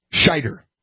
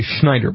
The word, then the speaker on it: Scheider: Scheider. That's how you say his name. Scheider. There's no N in there. I have an overwhelming urge to want to say Schneider, but it's Scheider.